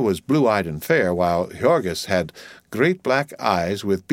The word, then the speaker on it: Jurgis: She was so young, not quite 16, and small for her age, a mere child, and she had just married and married to Jurgis, pronounced Jurgis of all men,